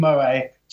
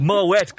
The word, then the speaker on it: Moët: So I'd just like to shout out to Elio and Jack Bolas, who are very much looking forward to buying me some Moët tomorrow in the champagne tent. – Moët, man. It's called Moët. God almighty, you can't be in Goodwood and pronouncing it wrong.